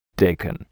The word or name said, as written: daikon